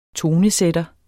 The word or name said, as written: tonesætter